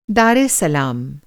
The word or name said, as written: दार एस सलाम